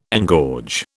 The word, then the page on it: engorge: British English